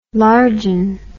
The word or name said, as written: largen